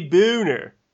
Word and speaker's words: Boehner: When someone like John Boehner, that's the alternate pronunciation. – It is the pronunciation. It's a German name, and in the German, it'd be Boehner.